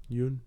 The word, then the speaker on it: Yeun: Steve Yeun. – Is that how you pronounce it? – Yeun. – Yeah. – Yeun. Steven Yeun.